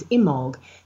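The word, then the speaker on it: Imbolc: It's Imolg. The B is silent, and the final C sounds kind of like a G. So it's Imolg, as if it was spelled I-M-O-L-G.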